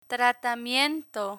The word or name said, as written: tratamiento